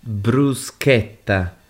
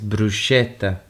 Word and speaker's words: bruschetta: Yeah, bruschetta. Because everyone says bruschetta, but the correct pronunciation is bruschetta.